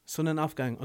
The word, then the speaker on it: Sonnenaufgang: They put together a little tour called Sonnen Aufgang. Did I say that right? – Sonnen Aufgang.